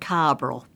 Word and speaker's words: carbaryl: Carbaryl. Old-fashioned carbaryl.